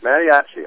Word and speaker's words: Mattiacci: Mattiacucci. I don't know. I don't even know how to pronounce the guy's name. – I think Mattiacci. – Mattiacci. Okay, well, we'll take that.